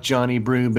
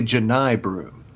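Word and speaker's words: Johni Broome: And this guy's name is spelled like Johnny Broome, but it's really Jani Broome.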